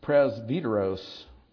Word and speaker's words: presbyteros: Presbyteros is the Greek modern Greek pronunciation of that word via the Latin presbyter.